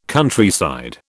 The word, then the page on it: Countryside: British English